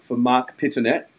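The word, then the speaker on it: Mark Pittonet: That is the pronunciation for Mark Pitonet.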